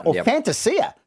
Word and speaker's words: Fantasia: But coming in at number five, Orazio Fantasia, Fantasia, technically, is how he says it should be pronounced.